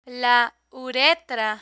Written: la uretra

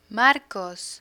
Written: marcos

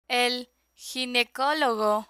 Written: el ginecologo

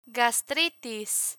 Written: gastritis

